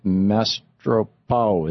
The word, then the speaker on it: Mastropaolo: Dr. Joseph Mastropaolo is with us. Am I saying that right, sir? – Mastropaolo.